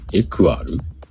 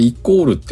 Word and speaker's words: equal: んなんて読むえ、エクアル ?EQUAL。余裕だもんね。あ、イコールか。これイコールって読むんだ。